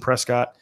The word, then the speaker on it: Prescott: So Bryce with a Y, one S, two T's, and Prescott.